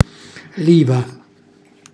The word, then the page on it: liva: Calabrese